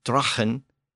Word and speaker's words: dragan: So, as we saw earlier, sagu eventually became saw, and the word D R A G A N, pronounced Drachen, it eventually became Draw, D R A W.